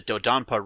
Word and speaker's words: Dodanpa: which is a mix of Doduitsu style with a Dodanpa rhythm, but with the emphasis on the pa, not the don.